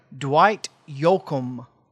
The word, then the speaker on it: Dwight Yoakam: Dwight Yoakam.